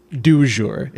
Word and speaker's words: du jour: Du jour. It's du, not de.